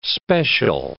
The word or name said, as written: special